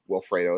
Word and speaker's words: Wilfredo: I think it's Wilfredo is how you pronounce it.